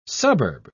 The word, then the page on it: suburbs: British English